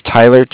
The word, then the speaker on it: Tyler: Okay, my name is Tyler, T-Y-L-E-R. I'm calling with Cardiff. It's spelled C-A-R-D-I-F-F, as in Frank Frank.